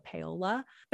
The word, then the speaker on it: Paoli: Paola is spelled P-A-O-L-I, but like a lot of towns in Oklahoma, the ending in I means you say it with an A sound. So Paola.